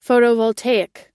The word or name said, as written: photovoltaic